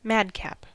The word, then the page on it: madcap: American English